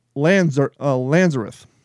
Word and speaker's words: Lanzerath: The stand at Lanzerath. That's L-A-N-Z-E-R-A-T-H.